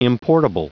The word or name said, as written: importable